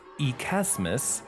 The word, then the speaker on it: Xmas: It is sometimes pronounced Ekesmas, but... it and variants such as Xtimas originated as handwriting abbreviations for the typical pronunciation Christmas.